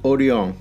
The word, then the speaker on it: Orion: No, that's not how you pronounce it. It's Orion.